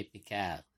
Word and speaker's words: Picard: She pronounces Picard the right way, Picard.